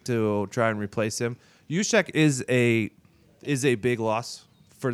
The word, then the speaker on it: Juszczyk: J-U-S. – Oh, Kyle Juszczyk.